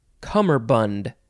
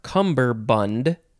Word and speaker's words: cummerbund: Next is cummerbund, or cumberbund. It is spelled C-U-M-M-E-R-B-U-N-D. Cummerbund. You can also put a B instead of the second M, which is why it can also be cummerbund.